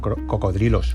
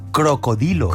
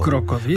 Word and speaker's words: cocodrilo: Bueno, pues nosotros deberíamos pronunciar no cocodrilo, deberíamos pronunciarlo crocodilo. – Crocodilo. – Porque viene del latín crocodilus. Pero ¿qué pasa? Que hay una cosa que se llama metátesis. Que es que muchas veces pues cambiamos de lugar las R trabadas, principalmente para facilitar la pronunciación. Entonces se aceptó cocodrilo, pero debería ser crocodilo si nos ponemos finos.